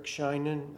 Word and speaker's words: Sheinin: My name is Eric Scheinen. I'll spell that for you. S-H-E-I-N-I-N.